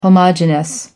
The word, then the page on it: homogenous: American English